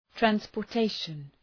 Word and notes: transportation: ,trænspər’teıʃən

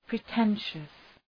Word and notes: pretentious: prı’tenʃəs

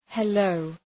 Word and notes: hello: he’ləʋ

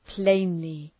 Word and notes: plainly: ‘pleınlı

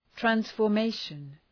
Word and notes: transformation: ,trænsfər’meıʃən

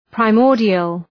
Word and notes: primordial: praı’mɔ:rdıəl

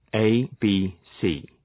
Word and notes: ABC: ,eıbi:’si:

